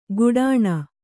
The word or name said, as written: ಗುಡಾಣ